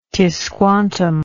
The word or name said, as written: Tisquantum